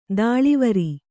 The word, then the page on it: ಧಾಳಿವರಿ: dhāḷivari